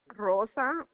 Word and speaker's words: Rosa: My name is Rosa Mitchell. I tell him yesterday I'm gonna call him today. – Okay, may I have your first and last name? Can you spell that for me? – Rosa Mitchell. – R-O-S-A-M-I-T-C-H-E-L-L.